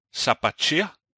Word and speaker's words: Sabatier: I dag så har jeg fået besøg virtuelt af Mark Hvidkær Sabatier, eller hvordan udtaler man det? Ja, det er nogenlunde det.